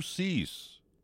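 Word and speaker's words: Suess: Well, mine's supposed to be pronounced Cease, but it looks just like Sue, so it's Seuss to most people.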